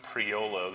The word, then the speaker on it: Priolo: And that one's written by Lou Priolo. That's an Italian last name, and that's spelled P-R-I-O-L-O, Priolo.